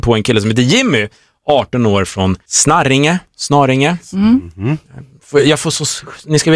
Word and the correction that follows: Snarringe: Men herregud, Snaringe. – Snarringe. – Säg ni då. Så är det två R eller? – Ja, det är två R.